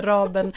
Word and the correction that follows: Rabén: rab, förlåt, Rabén och Sjögren fel.